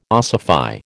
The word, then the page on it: ossify: American English